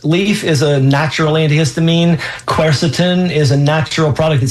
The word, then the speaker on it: quercetin: Quercetin.